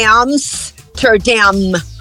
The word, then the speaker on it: Amsterdam: Então, você lembrando disso aí, você vai dizer am, Amsterdam, ou seja, tem dois M's, bilabial, sempre. Não existe um M na língua inglesa inteira que você possa pronunciar com os lábios abertos, fica horrível, é Amsterdam.